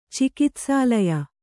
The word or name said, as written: ಚಿಕಿತ್ಸಾಲಯ